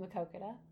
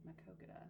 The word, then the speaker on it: Maquoketa: I grew up there, and then I Well, how do you pronounce it? – Maquoketa. – Maquoketa. – Mm-hmm. Mm-hmm. Yep.